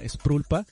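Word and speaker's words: sprul pa: El concepto viene de una adaptación occidental de ideas del budismo tibetano, que es una palabra que se pronuncia sprulpa, que significa emanación o manifestación.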